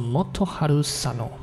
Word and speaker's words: Motoharu Sano: 汉字啊，罗马拼音的话念作 Moto Haru Sano，对，那日文的汉字就写作佐野元春，也是收录在一张叫做《 Moto Singles》的 1980 to 1989 的专辑。